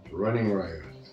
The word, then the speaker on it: Running Riot: Running Riot. – Voilà, tu le prononces mieux que moi.